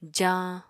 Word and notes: jañ: Apatani

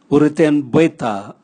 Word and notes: urutenbweta: Raga

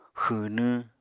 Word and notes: xwwnwñ: Apatani

